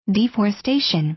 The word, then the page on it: deforestation: dìːf`ɔːrɪstéɪʃən; ディフォレステイション